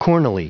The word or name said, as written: cornily